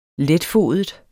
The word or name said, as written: letfodet